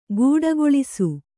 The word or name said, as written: ಗೂಡಗೊಳಿಸು